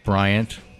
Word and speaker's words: Bryant: Bryant. It's spelled B-R-Y-A-N-T.